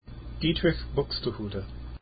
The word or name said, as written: Buxtehude